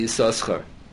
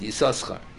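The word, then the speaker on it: Yissachar: Pirush Rashi and Dirayam is probably not by Rashi, but the Pirush Rashi and Dirayam writes that Yisoschar is a kriyaksiv. It's written with two shins, and you pronounce it with one shin.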